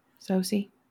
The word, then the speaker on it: Sosie: Sosie.